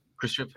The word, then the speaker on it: Khrushchev: But Nixon, being Nixon, got into an argument with Khrushchev. Is that how you say it? Khrushchev? – Khrushchev? – Khrushchev.